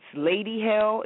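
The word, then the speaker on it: Ladi Hale: It's Lady Hell. It's not spelled the normal way. It's Lady, L-A-D-I, Hell, H-A-L-E. And then I have my Instagram, which is Epic Lady Hell.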